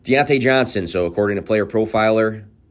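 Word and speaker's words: Diontae Johnson: So one more time, saying it correctly, Deontay Johnson ran the most routes of any wide receiver in the NFL.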